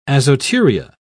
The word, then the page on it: azoturia: British English